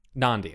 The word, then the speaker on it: Nandi: Nandi is how you say her name.